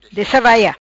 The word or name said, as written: Dessa vai ia